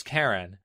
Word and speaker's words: Keren: Karen spells her name with an E instead of an A, but nonetheless pronounces it as Karen.